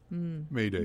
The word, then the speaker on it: Mayday: It comes from the French term, which means help me. – Ah. – And is pronounced Mayday.